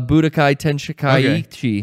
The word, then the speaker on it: Budokai Tenkaichi: This tournament is not a Budokai Tenkaichi. Okay. It is... Did I pronounce that right, Rocco? – Yes, you did.